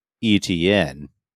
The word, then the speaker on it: Etienne: Or do you still have faith in Travis Etienne? I guess I might have pronounced it wrong. – No, Etienne's perfect. That was spot on.